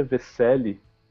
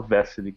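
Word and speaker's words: Veselý: O nome dele, a pronúncia original do nome dele é Vesely, mas ele deu uma xarapovada no nome dele. Ele mesmo fala Vesely, que é como os americanos chamam.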